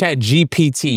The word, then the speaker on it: ChatGPT: ChatGTP has. – No. This is something else from ChatGPT. – Oh, this is from chat. Okay. – ChatGPT. You always say it wrong.